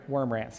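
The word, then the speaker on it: Wurmbrand: I was reading Richard Worm, how do you pronounce his last name? Wormbrandt, sorry. I didn't want to dishonor him by mispronouncing his name, to be honest. So I was reading Richard Wormbrandt just yesterday,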